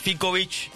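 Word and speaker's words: Ficovich: Fikovic.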